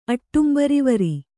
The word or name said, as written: ಅಟ್ಟುಂಬರಿವರಿ